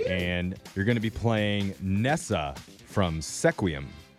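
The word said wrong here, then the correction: Sequim: Squim. – Squim. – You can say it right. – What? Why is there S-E? – That's how you say it.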